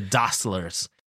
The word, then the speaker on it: Dassler: Well, if you pronounce it the German way, it's Dassler.You got to pronounce the very strongly. The Dasslers.